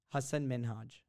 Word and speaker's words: Hasan Minhaj: But that's the Indian way. – Well, that's the right way. – Well, his right way. Well, Arabs, we say Hasan Minhaj.